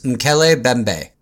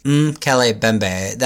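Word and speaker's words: Mokele-mbembe: The way I heard it is Nkele Mbembe. – Nkele Mbembe? – Nkele Mbembe. Mm-hmm. So, Nkele Mbembe. I was looking at a couple of YouTube videos, and that's how the local fishermen pronounced it.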